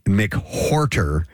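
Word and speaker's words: McWhorter: Taylor said her breakup to Mason McHorder. – Stop it. – It's built into it. – Yeah. – You have to be a hoarder. – And it is spelled W-H-O-R-T-E-R.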